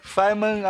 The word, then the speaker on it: Feynman: Atenção, Feynman se escreve Feynman, tá? Com F-E-Y-N-M-A-N, mas se pronuncia Feynman.